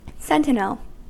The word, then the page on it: sentinel: American English